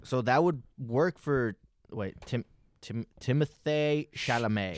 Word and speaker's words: Timothée: Apparently, his name is pronounced Timothée. – Timothée. – Yeah, because it's French.